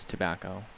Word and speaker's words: Tabacco: My last name really is Tabacco. It's slightly spelled differently. It's T-A-B-A-C-C-O, hence why it's spelled wrong.